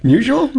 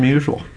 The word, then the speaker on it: Musial: I would say Musial. – Yeah, Stan Musial.